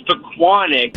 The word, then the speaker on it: Pequannock: Tommy, it's Pequannock, but even funnier, Pequannock, but I grew up in Secaucus.